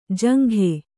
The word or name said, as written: ಜುಂಗ